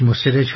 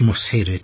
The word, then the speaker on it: مسهر: البته صحیحش مسهره، حالا توضیح ما در این مقطع جامعه بررسی کردیم، سعی کردیم اسما را تلفظ صحیح همه اسامی اشخاص اماکن و امثال اینا را به دست بیاوریم و با علامت گذاری مشخص کنیم که خوانندگانم صحیح بخونند. در مورد قیس ابن مصیر بررسی کردیم، صحیحش مصیره، تشدید نداره.